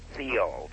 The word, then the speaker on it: Theall: Thiel. T-H-E-A-L-L. – You pronounce it a soft T-H.